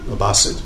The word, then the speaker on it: Abbasid: an Arab Muslim empire called the Abbasid empire. Abbasid, actually, it's pronounced.